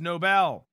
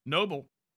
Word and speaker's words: Nobel: The vast majority understand how the Nobel Peace Prize is pronounced. Nobel, not Nobel.